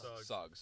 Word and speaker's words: Suggs: If Jalen Suggs was a few years, sorry, how do you say it? Suggs, sorry.